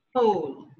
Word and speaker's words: Hohl: It's pronounced Hole. – Hole. Very good. So Alva Hole. I have Alva Hole with me. And let me tell you a bit about him.